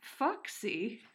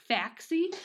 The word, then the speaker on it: Faxi: It's spelled F-A-X-I. So I'm really glad that I looked up how to pronounce it because I would have just been like, Faxy?